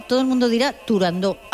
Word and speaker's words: Turandot: pues no había que decir Turandot, sino que, a pesar de que tuviera una T...